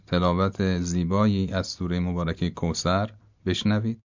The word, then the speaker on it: کوثر: مثلا کوثر به جای کوسر، نوک زبان یه مقدار که از بین دندون‌ها بیاد بیرون تلفظ درسته.